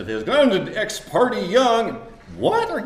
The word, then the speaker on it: Ex parte: One is how you pronounce Ex Parte Young. I think it's Ex Parte Young. How many of you think it's Ex Parte? Ex Parte? A surprising number of Supreme Court justices say Ex Parte.